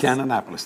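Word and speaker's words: Kannapolis: How do you say that? Cannonapolis? – Cannonapolis. That's right.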